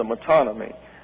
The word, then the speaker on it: metonymy: And therefore, the phrase, the throne, is a metonymy. That's spelled M-E-T-O-N-Y-M-Y, a metonymy.